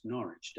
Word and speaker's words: Norwich: My parents they grew up together in a town called, you know, in America we say Norwich, Connecticut, but of course we all know it's pronounced Norwich, don't we? But you would say Norwich in America.